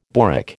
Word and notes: boric: American English

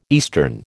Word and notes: eastern: American English

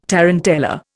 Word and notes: tarantella: Indian

